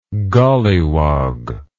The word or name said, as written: golliwogg